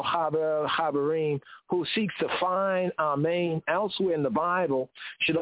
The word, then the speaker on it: amen: In the English, it's pronounced as an A, but in the Hebrew, Sephardic, as an ah. The Ashkenazi, the Eastern European Gomorite Yehudi, they pronounce it as an aw. The accent in both cases is on the second syllable, amen, amen.